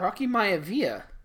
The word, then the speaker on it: Maivia: Was it Rocky Mafia? Or was it Flex Cavana? It was Rocky Mafia or Mavia or Maivia. – No, but Rocky Maivia.